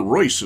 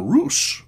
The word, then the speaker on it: Reuss: whose name I apparently have been mispronouncing. Alan Royce is what I was calling him. R-E-U-S-S, noted rhythm guitar player all through the 30s with Benny Goodman and a number of other bands. I've been told that he pronounced his name Roos, R-E-U-S-S. So there you go.